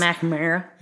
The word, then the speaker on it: McNamara: I just butchered your last name. Did you hear that? Like, I went like Southern and just took out some middle parts. – McNamara.